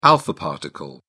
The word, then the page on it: alpha particle: British English